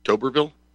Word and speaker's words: Tuberville: So what Senator Tuberville, is that how you say it? – Yes.